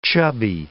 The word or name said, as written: chubby